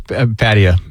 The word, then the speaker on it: Pattaya: Yes, it's pronounced Pattaya.